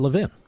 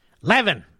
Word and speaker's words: Levin: No, the truth is, in Philadelphia, at least where I grew up, L-E-V-I-N is pronounced Levin. Much of the rest of the country, it's pronounced Levin.